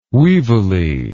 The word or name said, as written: weevily